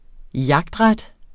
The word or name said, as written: jagtret